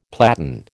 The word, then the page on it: platan: American English